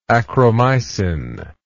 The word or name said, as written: achromycin